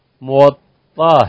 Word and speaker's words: موطأ: اصل اس کا تلفظ موطأ ہے. مو تو میم پہ آپ دیکھیں پیش آ گئی، واو پہ زبر آ گیا، اور طا جو اس میں آتا ہے یہ مشدد ہے، وہ 2 طا ہیں اور انہیں ایک کیا گیا ہے.